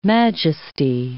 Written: majesty

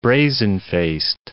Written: brazen-faced

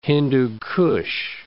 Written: Hindu Kush

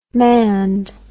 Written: mand

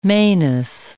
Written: manus

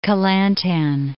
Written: Kelantan

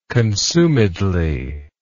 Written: consumedly

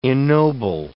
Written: ennoble

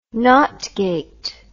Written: NOT gate

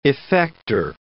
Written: effector

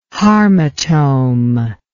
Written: harmotome

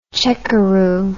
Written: checkeroo

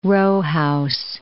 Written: row house

